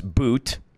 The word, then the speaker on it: Bout: Instead of doing a swap for what is now the Russian arms dealer, Viktor Boot, it's spelled B-O-U-T, but it is pronounced Boot.